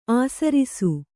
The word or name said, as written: ಆಸರಿಸು